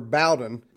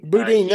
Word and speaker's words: Boudin: This latest one, rogue prosecutors Gascon, and do you say his name Boudin or Bowden? – Chase of Boudin. – Boudin, that's what I thought. I thought down here they would have Anglicanized it to Bowden, but it's Boudin and panicking over recall elections.